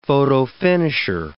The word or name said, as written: photofinisher